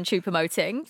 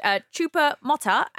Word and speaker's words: Choupo-Moting: Martin Keown calling him everything other than Choupo-Moting throughout the match. Choupo-Mota and Choupo-Mota.